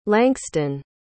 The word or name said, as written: lankston